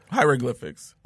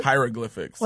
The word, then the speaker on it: hieroglyphics: Is it called hydroglyphics? – Mm-hmm. – They got hydroglyphics of people getting a goddamn colon cleanse, man. – Hieroglyphics. – Well, how do you say it? – Hieroglyphics.